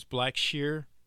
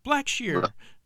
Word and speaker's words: Blackshear: It's spelt like it's pronounced like it's spelled. And mm-hmm. I would have people butcher it all the time. It's like, you're looking at it. It's black and sheer. Blackshear.